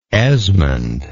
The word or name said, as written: Esmond